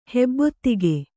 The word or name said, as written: ಹೆಬ್ಬೊತ್ತಿಗೆ